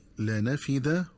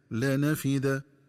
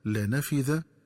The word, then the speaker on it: لنفد: ينبغي التنبه للنطق الصحيح للفظي لنفد وتنفد، فيقرآن بالدال فيهما هكذا: لنفد تنفد، ومعنى نفد فني وانتهى. ومن الخطأ قراءتهما بالذال هكذا: لنفذ وتنفذ. وكذلك عند قراءة الآية التي تليها في قوله تعالى: فمن كان يرجو لقاء ربه فليعمل صالحا ولا يشرك بعبادة ربه أحدا.